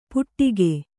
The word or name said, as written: ಪುಟ್ಟಿಕೆ